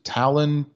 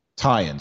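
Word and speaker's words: Taillon: Is it Talon? Tyon? How do you pronounce his last name? Tyon. – Tie-in.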